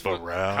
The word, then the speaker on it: Barão: I love how you pronounce as opposed. We just Barão. – It is Barão.